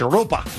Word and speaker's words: Europa: Europa. That's how you say it in Spanish. Europa.